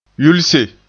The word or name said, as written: yulse